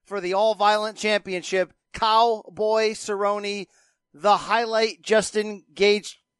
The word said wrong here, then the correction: Gaethje: Brandon, can you give me the real pronunciation there? – Justin Gaethje. – Gaethje's got it.